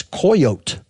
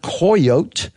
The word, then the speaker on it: coyote: which was the original pronunciation was coyote. The Spaniards heard coyote, the Indian word. They converted it to coyote in a Latin pronunciation.